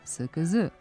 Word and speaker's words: SKZ: Citiramo, u nekim skraćenicama nazivi slova izgovaraju se prema normi, to jest jedan glas za jedno slovo. Naprimjer, SKZ čita se SKZ, a znači Srpska književna zadruga. A u drugim skraćenicama se čita prema ustaljenoj praksi. Naprimjer, RTS, Radio Televizija Srbije, izgovara se RTS.